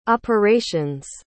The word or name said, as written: operation's